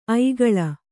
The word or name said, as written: ಐಗುಳ